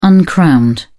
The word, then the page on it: uncrowned: British English